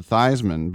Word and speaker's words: Theismann: Joe Theismann changed his pronunciation to Theismann because he thought it would help him win the Heisman.